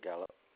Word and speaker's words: Gallop: Yeah, I'm assuming Gallup is spelled exactly how it sounds, like the word. – Yeah, G-A-L-L-O-P.